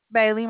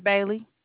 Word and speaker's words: Bailey: Vaniella is spelled V-A-N-I-E-L-L-A. – And what is your name, or just a representative number? – Bailey. My name is Bailey. B-A-I-L-E-Y.